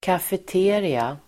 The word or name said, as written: kafeteria